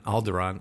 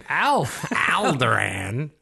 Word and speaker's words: Alderaan: Princess of the destroyed planet of Alderaan. Alderaan. I don't know why I said it wrong, but I felt like it. Alderaan.